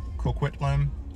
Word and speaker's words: Coquitlam: Coquitlam. – Hope I'm pronouncing that right. Yep. Yeah. It's about 45 minutes from the airport.